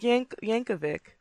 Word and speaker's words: Yankovic: Yankovic or Yankovic? I don't know. – It's Yankovic. – Okay, I don't know how it's pronounced, because I've heard it's Yankovic. – Yankovic. Okay, I was right the first time. Didn't need to go down that road. – No. It's pretty much phonetic.